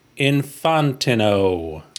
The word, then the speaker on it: Infantino: He can confirm, after meeting Carmine in 1998, he pronounced his last name Infantino.